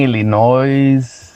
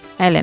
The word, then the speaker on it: Illinois: Cuando yo estaba allá, yo pronunciaba Illinois. – Illinois. – Cuando estoy aquí en California, que tengo más de 30 años de vivir acá ya, 30 años, yo digo Illinois. Pero cuando estaba allá, yo decía Illinois.